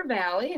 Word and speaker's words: Vally: Okay, well, here's all my name is Jennifer Valley, and that's spelled V-A-L-L-Y, no E in Valley.